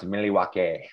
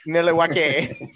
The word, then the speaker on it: Milwaukee: Milwaukee. – It's actually pronounced Milwaukee. – Milwaukee.